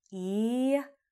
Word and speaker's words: IAO: One of the main mantras that is taught in The Perfect Matrimony is I-A-O, which is pronounced as in Latin, E-A-O.